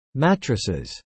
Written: mattresses

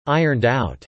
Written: ironed out

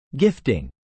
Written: gifting

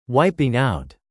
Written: wiping out

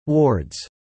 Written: wards

